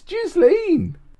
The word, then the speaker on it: Ghislaine: How do you say Ghislaine? Ghislaine. It's Ghislaine.